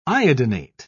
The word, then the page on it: iodinate: British English